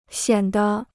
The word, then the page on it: 显得: xiǎn de